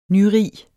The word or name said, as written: nyrig